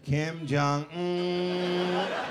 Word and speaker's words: Kim Jong Un: Kim Jong Un.